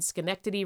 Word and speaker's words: Schenectady: Tourism was greatly aided by the 1832 arrival of Saratoga and Schenectady Railroad.